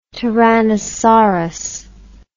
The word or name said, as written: tyrannosaurus